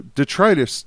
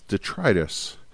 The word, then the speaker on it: detritus: That was the way that I had heard the term, and the person who pronounced it when I learned about it called it detritus. So they had it wrong, and I have now going to correct my ways, but I have been perpetuating that problem.